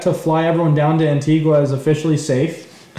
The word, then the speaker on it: Antigua: Antigua, in Barbuda, I'm pretty sure.